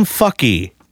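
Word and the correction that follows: Fuki: I realize it's probably Fooky. – Fooky.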